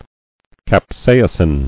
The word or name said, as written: Capsaicin